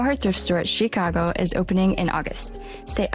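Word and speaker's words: Tchiikago: Am I saying it right? – Yes. Okay. I think people say it differently. Like Chicago. It's fine. Both ways work.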